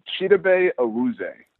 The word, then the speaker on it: Chidobe Awuzie: It's Chidobe Awuzie.